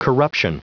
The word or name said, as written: corruption